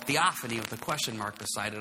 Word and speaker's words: theophany: So it's theophany.